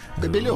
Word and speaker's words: Кобелев: Экс-тренер Динамо Кобелев или Кобелев? Кобелев? – Кобелев. Ударение не поставлено.